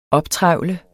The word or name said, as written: optrevle